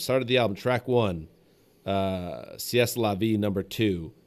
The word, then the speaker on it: C'est la vie: C'est la vie is the correct pronunciation.